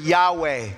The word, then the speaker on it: Yahweh: Now, if you pronounce this in the Hebrew, this is where we get the word Yahweh. No consonants, just, it's like, almost like just breath and spirit.